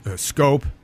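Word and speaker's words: Schoop: You thought they were good signings, but I do because Krohn, Scope.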